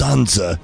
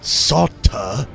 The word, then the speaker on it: Sansa: Oh, Maester Rhaenyra, it's pronounced Sansa, not Sauta, and I don't think that's real love.